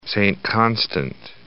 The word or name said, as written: Saint-Constant